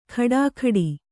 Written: ಖಡಾಖಡಿ

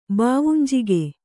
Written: ಬಾವುಂಜಿಗೆ

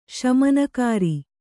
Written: ಶಮನಕಾರಿ

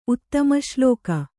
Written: ಉತ್ತಮಶ್ಲೋಕ

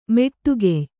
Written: ಮೋಟಕೆಯ್

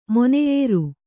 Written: ಮೊನೆಯೇರು

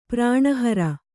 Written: ಪ್ರಾಣಹರ

